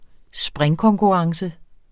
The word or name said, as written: springkonkurrence